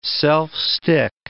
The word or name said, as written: self-stick